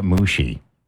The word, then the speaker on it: Muxi: I think it's M-U-X-I, but you say it Mushi.